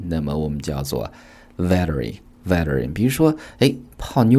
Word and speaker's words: veteran: veteran 这个单词美式发音读作 veteran。